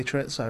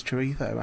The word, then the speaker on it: chorizo: Because I say chorizo and Ed said, no, it's chorizo. And I've just said chorizo ever since.